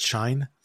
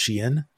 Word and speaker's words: Shein: Like I always called it Shine, and many people call it Shine, but it's actually pronounced Shein.